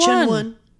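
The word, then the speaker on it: Qinwen: Chen Wen. – Yeah. – Did I say it right? – You're sounding like you actually are pronouncing it right.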